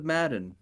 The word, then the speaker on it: Medan: It's spelled M-E-D-A-N for Medan. – Man of Madden.